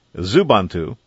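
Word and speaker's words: Xubuntu: Zubuntu. I'm presuming that's pronounced with a Z, but it's actually an X at the beginning of the word.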